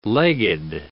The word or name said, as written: legged